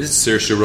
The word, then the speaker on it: Saoirse: This is the new Neil Jordan vampire movie with Saoirse Ronan. I'll say it so you don't have Saoirse. – To. – Saoirse.